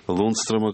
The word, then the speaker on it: Лундстрема: Лундстрема, как правильно говорить.